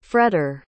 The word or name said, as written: fretter